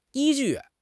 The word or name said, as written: 依据